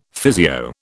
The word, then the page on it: physio: British English